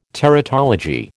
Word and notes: teratology: American English

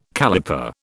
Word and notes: caliper: British English

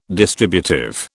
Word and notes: distributive: British English

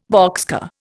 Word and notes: boxcar: Indian

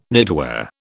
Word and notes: knitwear: British English

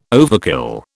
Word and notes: overkill: British English

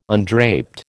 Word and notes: undraped: American English

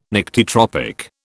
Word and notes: Nyctitropic: British English

